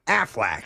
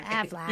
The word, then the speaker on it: Aflac: And American Family Life Insurance Company of Columbus is better known by the acronym Aflac. – Aflac. You have to say it like that.